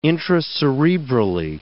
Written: intracerebrally

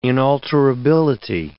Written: inalterability

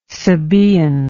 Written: sabaean

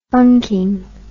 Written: funking

